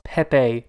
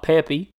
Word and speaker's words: Pepe: My Disney Channel lesson is it's pronounced Pepe, not Peppy.